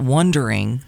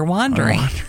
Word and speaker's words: Wandering: They're not Wondering. They're Wandering. Yeah. – There's an A, not an O.